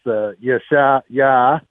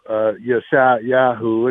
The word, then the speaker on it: Yeshayahu: So his name in Hebrew is Yeshayah, or also Yeshayahu. It's spelled both ways.